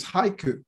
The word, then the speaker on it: haiku: So yeah, but the pronunciation's haiku. – Okay, very singular. Okay, now I feel better. I remember haiku. That and try to try not to say haikus in plural.